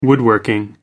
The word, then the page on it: woodworking: British English